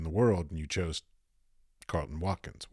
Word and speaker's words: Carleton Watkins: Carlton Watkins.